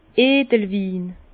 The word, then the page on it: Edelwin: Bas-Rhin; Herrlisheim